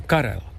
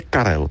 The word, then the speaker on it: Karel: A tak to se to vyslovuje, Karel prostě. – Normálně Karel, přesně tak, jak jsme zvyklí u nás.